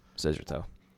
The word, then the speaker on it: Szyarto: You can also find him on YouTube at the name of Jeffrey Ciarto, spelled the same way, but this time pronounced Scissorto. – That's correct.